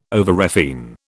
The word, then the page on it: overrefine: British English